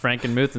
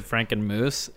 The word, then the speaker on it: Frankenmuth: Cash mispronounced Frankenmuth and said Frankenmoose, and then I said, why is that not some of their like Halloween decorations?